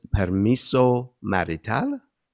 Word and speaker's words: permiso marital: And the term is permiso marital. Did I pronounce that right? – Yes, yeah.